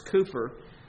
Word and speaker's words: Cowper: It is spelled Cowper, but it was pronounced Cooper.